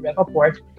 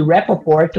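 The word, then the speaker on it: Rapoport: inclusive, na, na, na, série, já que esses grupos eram muitos, esses grupos ditos terroristas da terceira onda do, do Rappaport, era muito utilizada pelos, é, pelos narcotraficantes como massa de manobra, é, é para deixar o, o, Estado ocupado, principalmente.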